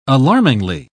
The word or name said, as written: alarmingly